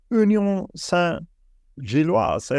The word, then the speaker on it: Union Saint-Gilloise: Eh, fem mål hittills eh, för Union Saint-Gilloise. Är det så man uttalar det? – Ja, ja bra uttal.